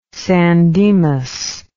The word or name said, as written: san dimas